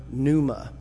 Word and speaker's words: pneuma: You're probably familiar with the Greek term pneuma. It's spelled P-N-E-U-M-A. It's where we get pneumonia, right?